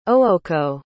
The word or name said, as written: ooko